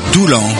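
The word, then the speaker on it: Doullens: Mais pour le moment, je tiens à vous rappeler que là, le dimanche 13 septembre, je serai avec tous mes musiciens à Doulan, faut prononcer les normes, mais il y a un S à la fin quand même. Bon, alors, à l'espace culturel, à partir de 14h30 de Doulan.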